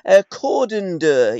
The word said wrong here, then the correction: Cardenden: is that how you pronounce the area that you were born, Tommy? – I was born, strangely enough, it comes under Cardenden. – Got ya. – It's Cardenden, but I was actually born in Dundonald, which is sort of a little village.